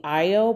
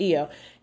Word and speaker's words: Ayo: It's spelled A-Y-O. And to me, that would be I-O. But I believe it's pronounced EO.